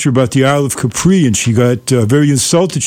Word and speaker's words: Capri: She said, no, it's pronounced Capri. So ever since then, whenever I see the word, I pronounce it the way the Italians do, which is Capri.